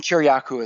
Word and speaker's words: Kiriakou: By the way, so this is a good time to jump in and just say John Kiriakou, I'm probably mispronouncing his name, big-time whistleblower who went to prison. He's going to be on the show sometime in the next month or so. I'm reading his book. – And he's in that group. – I'm sure he is. – Yes, and Kiriakou is, I believe, the correct pronunciation.